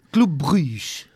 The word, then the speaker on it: Club Brugge: Club Brugge.